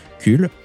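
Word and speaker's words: KUL: Eh bien, c'est ce qu'a fait une équipe scientifique du CUL. On ne sait pas où c'est, c'est en Belgique. Et ça s'écrit avec un K. Et le L, il se prononce.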